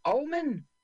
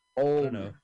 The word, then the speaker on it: Oldman: Oldman.